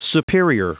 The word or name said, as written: superior